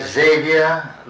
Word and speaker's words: Xavier: Xavier Lopez. – How do you spell your first name? – X-A-V-I-E-R. – X-A-V-I-E-R. Lopez, L-O-P-E-Z.